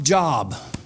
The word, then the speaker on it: Job: Ain't it J-O-B? How you say it? J-O-B? How do you pronounce it? – Job. – Job. Oh, Job.